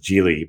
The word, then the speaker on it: Gili: Cause like the it's pronounced Gili, but people call it Gili Islands.